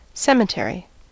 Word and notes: cemetery: American English

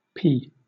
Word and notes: pee: British English

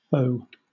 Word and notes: faux: British English

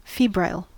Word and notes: febrile: American English

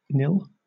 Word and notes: nil: British English

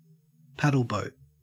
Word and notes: paddleboat: Australian English

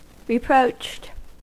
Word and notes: reproached: American English

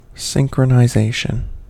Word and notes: synchronization: American English

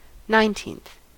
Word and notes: nineteenth: American English

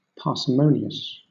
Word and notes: parsimonious: British English

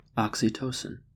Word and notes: oxytocin: American English

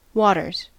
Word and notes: waters: American English

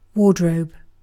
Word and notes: wardrobe: British English

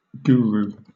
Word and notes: guru: British English

